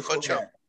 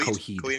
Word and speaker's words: Coheed: Coheed or Coheed? – That's Coheed. Cool. Coheed and Cambria. – Okay. – Sorry. – Coheed. I said it wrong.